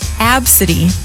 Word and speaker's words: ABCDE: But this one, it's spelled A B C D E. – Uh-huh. – How's it pronounced? – Absidy.